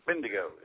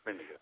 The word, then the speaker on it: Bendigo: Yeah, great to have you back on all the way from Bendigo, Australia, if I'm pronouncing that correctly. – Bendigo. Yeah, it's Bendigo.